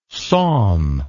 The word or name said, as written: sawm